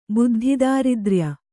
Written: ಬುದ್ಧಿದಾರಿದ್ರ್ಯ

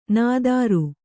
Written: ನಾದಾರ

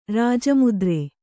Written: ರಾಜಮುದ್ರೆ

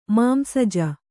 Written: ಮಾಂಸಜ

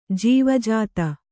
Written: ಜೀವಜಾತ

